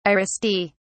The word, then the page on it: aristae: British English